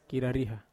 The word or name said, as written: karariha